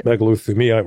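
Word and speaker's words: megalothymia: megalithemia, right.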